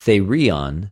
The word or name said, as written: θηριον